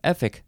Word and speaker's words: Efik: And yeah, Efik, that looks like it's the right pronunciation.